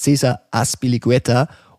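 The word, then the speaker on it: César Azpilicueta: es ist Cesar Aspiligueta und ist er eine Bayern-Alternative zu Kyle Walker? True or not true? Das ist true. – True. Cesar Aspilicueta ist der Kapitän vom FC Chelsea.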